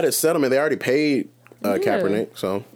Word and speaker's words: Kaepernick: And but even if, why would he have to wait for, Kaepernick or whatever the Kaepernick. – Kaepernick. – Yeah. Got it. It's spelled weird, but it's easy to say.